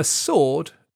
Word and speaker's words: sword: A sword is like a really big knife that was used by soldiers in battles. These days, the most famous swords are Japanese samurai swords. The word has a silent W.